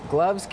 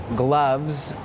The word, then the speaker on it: gloves: หมดเวลาค่ะน้องนิต้าไม่เป็นไรนะคะเดี๋ยวเราไปดูกันดีกว่าค่ะว่ามีคำไหนบ้างที่เมื่อกี้เราข้ามไปนะคะคำว่า gloves แปลว่าอะไรรู้ไหมคะนิต้าอะไรนะคะสะกดได้ไหมลูก S gloves gloves can be tricky because the V sound in the middle gloves also it's important to hear the S at the end of plurals